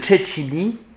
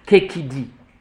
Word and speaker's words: cecidi: Et donc, en ce qui concerne la linguistique, on a essayé de faire, en retrouvant la formation des mots, au lieu de Cecidi, Kekidi, la prononciation du temps des Romains. Cecidi, c'est une prononciation populaire, avec le temps, avec les siècles. Donc, actuellement, vous avez depuis, de manière très sereine, depuis plusieurs siècles, cette prononciation-là, donc on l'a retenue.